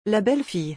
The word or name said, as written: La belle fille